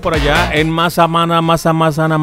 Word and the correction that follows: Mapastepec: Mazapritepiqui. Mapastepec, ya lo dije bien.